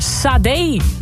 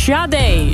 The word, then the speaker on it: Sade: Jij had het over Sade. Maar die naam spreek je uit als Sade.